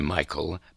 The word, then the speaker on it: Mihail: He pronounced his name Michael Mihail in the Irish manner.